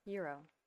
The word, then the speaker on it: gyro: How do you pronounce it? G-Y-R-O. – Euro. – Okay. – Euro. Yeah. – Okay, good.